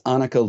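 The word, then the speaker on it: Anneke: It's Annika Lucas.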